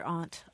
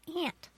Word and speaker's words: aunt: In standard English, there is in African American vernacular English, you hear aunt a lot. – Mm-hmm. – But in what we call standard English, the general pronunciation in this country is aunt.